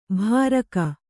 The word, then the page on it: ಭಾರಕ: bhāraka